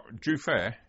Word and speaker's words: Jufair: Anything else happened while you were at Jufari? – No, not Jufair. – Jufair, sorry.